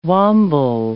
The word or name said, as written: wamble